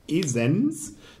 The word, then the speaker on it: Esens: Damit können wir aber auch Reisen machen in so ferne Gegenden wie Bremen, Leer, Emden, Aurich, Esens, nicht Essen, Esens.